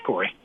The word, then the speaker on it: Cory: My name is Corey. – Corey, from that TV show, right? – No, it's spelled C-O-R-Y.